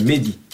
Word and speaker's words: Mahdi: mais par contre, je vais dire vous corriger euh, l'erreur francophone, euh, c'est Mehdi euh, qu'on prononce. Ça s'écrit avec un A.